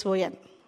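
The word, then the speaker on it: Waddesdon: Watson's Manor. Is it right? You heard about that? Okay. That is Waddesson. Waddesson, yes. I said I'm spelling it wrong.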